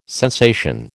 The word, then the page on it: sensation: American English